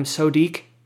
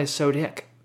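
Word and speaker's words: Sodiq: Of course, everyone was calling him Sodik. Which is, that's not how you pronounce it, okay? It's definitely Sodik, alright? That's how you say it.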